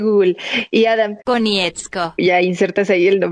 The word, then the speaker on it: Konietzko: Brian Konietzko.